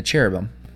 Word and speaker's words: cherubim: Um, the cherubim. Am I saying that right? – Yes. Okay.